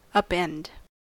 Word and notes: upend: American English

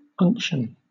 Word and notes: unction: British English

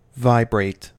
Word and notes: vibrate: American English

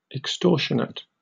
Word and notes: extortionate: British English

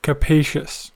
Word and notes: capacious: American English

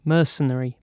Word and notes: mercenary: British English

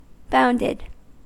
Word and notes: bounded: American English

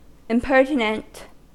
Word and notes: impertinent: American English

